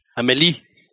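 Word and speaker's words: Amélie: And they did it like in, have you ever seen like the movie Amelie? – Yes. – Or Amelie, or I don't know how to pronounce it. – Amelie. – There you go.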